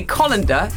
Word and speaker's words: colander: He says we are pronouncing C-O-L-A-N-D-E-R wrongly. – I've no idea how I say it. – That's what we're supposed to be using today. It's one of the suggestions that we use a colander in my world. – Yes, yes. – And he says that we should say colander.